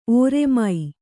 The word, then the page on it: ಒರ್ಮೆ: orme